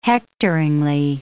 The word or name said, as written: hectoringly